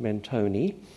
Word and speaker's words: Mentone: The gangsterish real estate developers of boomtime Melbourne were advertising Mentone, which they pronounced Mentone, the Italian style.